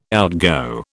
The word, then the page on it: outgo: British English